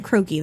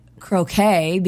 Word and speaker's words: croquet: She also said croaky, though. So. – Yeah. – Well, that's how they say it in London. – Yeah. – And actually, Luann said croquet because it sounds more elegant,